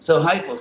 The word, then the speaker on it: hypo: So they put an H in front of the Sanskrit upa, and also they pronounce the U like a French or German U, so they said upa. And so when that Greek spelling came to English, it was just spelled hypo.